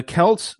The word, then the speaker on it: Celts: I gotta interrupt and say thank you for pronouncing Celts correctly. – Yeah, what do people say? Celts? – Celts. Because it's the Celtics. Celtics, but thank you.